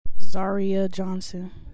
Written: Zaria Johnson

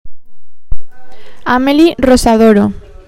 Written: Amelie Rosadoro

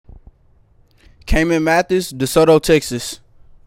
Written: Caimon Mathis

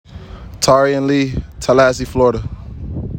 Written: Tarian Lee Jr.

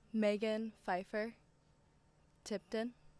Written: Megan Phifer

